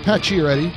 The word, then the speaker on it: Pacioretty: Max Pacioretty. Did I say that right? – Pacioretty.